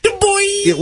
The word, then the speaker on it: DuBois: Dubois, or is it Dubois? I don't know how to pronounce it, but it's in Pennsylvania. – It's Dubois!